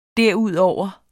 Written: derudover

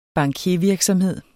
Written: bankiervirksomhed

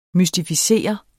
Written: mystificere

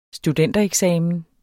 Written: studentereksamen

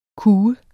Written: kue

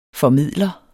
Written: formidler

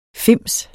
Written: fims

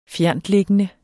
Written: fjerntliggende

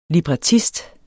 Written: librettist

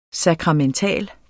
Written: SAKRAMENTALT

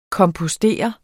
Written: kompostere

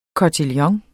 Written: kotillon

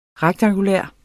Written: rektangulær

